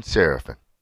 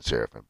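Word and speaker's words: Séraphin: His comp for me is Kevin Serafin. That's how he liked to have it pronounced. Some of you are going to say Kevin Serafin, but it's Kevin. He was French.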